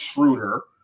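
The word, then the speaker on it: Schröder: because y'all been saying his name wrong this whole this whole podcast. It's Schroeder.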